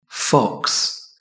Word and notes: fox: British English

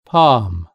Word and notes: palm: British English

